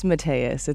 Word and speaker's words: Mateus: It's Mateus.